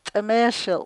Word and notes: t'emeeshil': Chukchansi

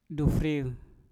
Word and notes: dufriu: Mpur

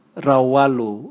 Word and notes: rauwalu: Raga

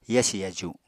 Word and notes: yasi yaju: apatani

